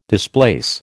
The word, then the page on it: displace: American English